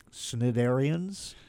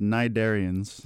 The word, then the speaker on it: Cnidarians: Because your album is called Return of the Snidarians? How do you say that? – It's pronounced Nidarians. – The C is silent, I see.